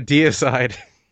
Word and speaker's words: Deicide: You know, I had a kid in my high school who always wore those, or Deceed. Is that how you pronounce that band? – Oh, Deicide. – Deicide. Deceed. Oh, look at me, Mr. When I first saw it, yeah, I was like, it's Deicide, what? – Deicide.